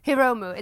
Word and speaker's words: Hiromu: which is Will Ospreay losing to Hironomu Takahashi for the Junior Heavyweight Champions. Yeah. Hironomu? Is that right? – Hiromu. It's Hiromu. – Hiromu Takahashi. Yeah. See? – Man is silent.